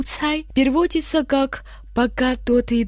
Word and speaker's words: 猜: «Цай» в первом тоне означает подозрение, недоверие, сомнение.